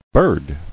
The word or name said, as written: Birding